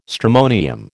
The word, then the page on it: stramonium: American English